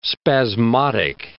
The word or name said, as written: spasmodic